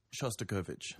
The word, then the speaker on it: Shostakovich: Shostakovich.